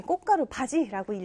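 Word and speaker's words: 꽃가루받이: 여러분, 여기에서 선생님이 꽃가루 바지라고 읽었어야 되네요.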